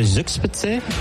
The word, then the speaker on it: Zugspitze: You know, I think the Zugspitze, did I say that right? – Yeah, it was pretty good.